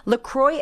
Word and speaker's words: LaCroix: It's LaCroix Sparkling Water. By the way, I used to pronounce it LaCroix, like the designer, but that would be wrong. It's LACROIX. Have you seen it? It's pronounced LaCroix.